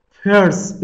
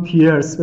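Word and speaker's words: Peirce: پرس به تلفظ آمریکایی که تو فارسی به نظر من پرس یه ذره تلفظش ممکنه ثقیل باشه تلفظ فرانسویشو من معمولا استفاده کنم پیرس به تلفظ فرانسویش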